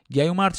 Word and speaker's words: گیومرت: گیومرت شکل قبلی تلفظ کلمه ای که امروزه میگیمش کیومرس